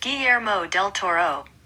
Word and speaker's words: Guillermo del Toro: According to Hollywood Reporter, pronounced Guillermo del Toro. Full name Guillermo del Toro.